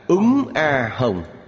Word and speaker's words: Úng A Hồng: Ba chữ này đọc là Úng A Hồng.